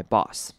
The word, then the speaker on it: boss: I want to be judged by my boss. 好，今天最后一个重点的是 boss 这个字，这个字很多人很常念错，要念成短母音 o r 的声音 boss。 Boss, 而不会念成长母音 O, 或者是念成 Boss, 所以要念成 Boss, Boss 的声音。